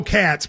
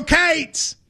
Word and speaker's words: Cats: Really, it's go Cats, but we in Kentucky would go go Cates, go Cates. – You're adding a syllable. It's like one syllable.